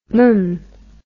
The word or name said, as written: mm